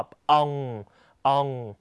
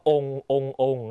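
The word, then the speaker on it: ông: เอินอูนอื่นต่อมานะครับพยัญชนะท้ายเงะนะฮะพร้อมกันอางอังเอิงแององนะครับสังเกตตัวนี้เสียงนะครับเราจะทำปากกว้างและปิดปากด้วยนะฮะอีกรอบององต่อมาองภาษาเวียดนามมีคำหนึ่งที่น่าสนใจนะครับคือองกับองนะฮะตัวนี้คนไทยออกเสียงผิดเยอะนะครับคำว่าองภาษาเวียดนามแปลว่าปู่นะฮะคนไทยชอบออกเสียงว่าององนะฮะจริงๆไม่ใช่จริงๆต้องออกเสียงว่าองนะ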